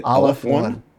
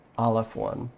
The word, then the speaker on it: Aleph-1: Aleph-1. Aleph-1. It's a Hebrew letter and a math symbol.